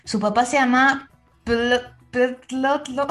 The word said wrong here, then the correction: Ptolomeo: ¿Cómo se llamaba? – Ptolomeo. Se escribe P-T-O. Ptolomeo.